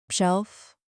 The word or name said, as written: shelf